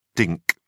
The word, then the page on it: dink: British English